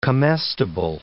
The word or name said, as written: comestible